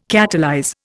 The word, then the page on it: catalyze: Indian